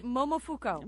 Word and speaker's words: Momofuku: Momofuku.